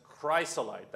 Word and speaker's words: chrysolite: Verse 20 says, the fifth onyx, the sixth ruby, the seventh, then that's chrysolite. That's how I'm going to pronounce it, like it or not.